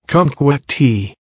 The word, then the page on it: kumquat tea: American English